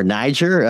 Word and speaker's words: Niger: That is the news coming out from everyone's favorite country in the Sahel, Niger, or Niger, as most of my colleagues would pronounce it.